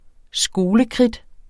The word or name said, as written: skolekridt